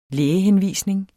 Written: lægehenvisning

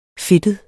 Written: fedtet

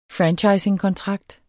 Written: franchisingkontrakt

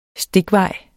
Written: stikvejs

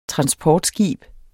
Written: transportskib

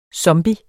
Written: zombie

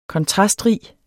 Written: kontrastrig

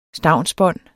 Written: stavnsbånd

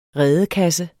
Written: redekasse

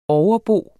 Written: overbo

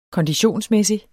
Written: konditionsmæssig

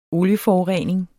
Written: olieforurening

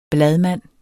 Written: bladmand

